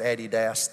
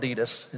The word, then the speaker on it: Adidas: We don't pronounce it Addy Dass, though. We call it Adidas.